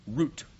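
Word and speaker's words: Root (רות): The book of Ruth, or as it's pronounced in Hebrew, Root.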